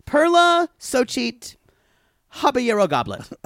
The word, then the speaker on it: Xochitl: X O C H I T L, which is pronounced, I believe, Xochitl.